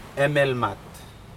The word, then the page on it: emelmat: Aneityum